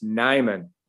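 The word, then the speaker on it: Nijman: The J is silent. It's Nyman. Yash Nyman.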